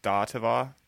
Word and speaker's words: Saatva: And the one she's talking about is called, I think it's pronounced Stativa. – Yes. – S-A-A-T-V-A. – Yes. – Writing this down. – And Casper. – S-A-A-T-V-A.